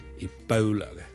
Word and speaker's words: Ebola: 最近如果大家有睇國際新聞咧，都應該會留意到 Ebola 呢個伊波拉病毒咧喺某啲地方肆虐咁樣。 Ebola 伊波拉病毒咧，我哋雖然廣東話我哋譯做伊波拉波啦，我哋係 O 一個發音，但如果英文咧，我哋通常咧係讀 Ebola 嘅 Ebola bow bow，好似弓箭嘅弓嗰個 bow。